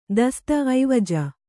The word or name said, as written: ಐವಜು